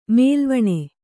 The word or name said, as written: ಮೈಲವಣಿ